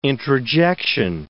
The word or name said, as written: introjection